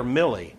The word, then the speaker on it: Milley: Is his name pronounced Miley or Millie? – Millie Vanilli. – That's how. Yeah, that's how you remember.